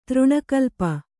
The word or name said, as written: ತೃಣಕಲ್ಪ